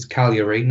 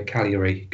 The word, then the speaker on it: Cagliari: However, it's Cagliari, not Cagliari. There's a silent G. So Cagliari, going forward, please.